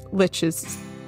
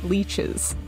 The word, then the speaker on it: lich: Did I say that right this time? Lich? – Yeah. Yep. – Sick. Mm-hmm. So good. I'm on top of this. All right. – Lich Tato, okay. Lich Tato. Right? – I think I almost always call them leeches, which is not... – Guys, it's pronounced lick.